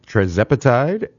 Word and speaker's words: tirzepatide: tirzepatide and semaglutide.